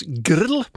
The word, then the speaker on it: G.R.L.: Ett okänt band, eller en okänd artist för mig, men i alla fall G.R.L. uttalas grill.